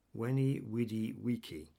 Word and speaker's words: veni, vidi, vici: Veni, vidi, vici. The Latin W is how it's pronounced, but it's spelt as a V.